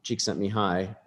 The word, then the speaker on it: Csikszentmihalyi: Csikszentmihalyi, I would consider this the... – Congratulations sequel. On sticking the landing on the pronunciation.